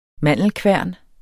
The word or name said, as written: mandelkværn